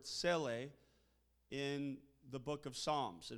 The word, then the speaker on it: Selah: The word we pronounce in the English language, Selah, is actually pronounced Seleh in the original, and it means, its literal definition means to lift up or to exalt, but its use is a bit ambiguous.